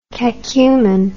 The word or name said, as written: cacumen